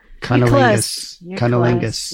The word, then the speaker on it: cunnilingus: Cummingus? Am I saying it right? – Cummingus. – Cunnilingus.